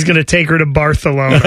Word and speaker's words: Barcelona: Well, that's the way you're supposed to say it, is Barcelona. – Barcelona. Yeah. Was it The Internship? – So you're supposed to pronounce the soft C is supposed to be Barcelona.